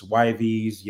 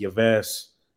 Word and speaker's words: Yves: Yo, you know what's funny about my name is nobody knows how to pronounce it here in the U.S., right? So I done heard Ives, Yves, Yves, everything.